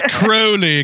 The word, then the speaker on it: Crowley: It's Crowley, because it rhymes with Holy. – Rhymes with Holy, yes.